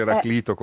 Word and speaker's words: Eraclito: Mi piace perché lei dice Eraclito con la pronuncia greca.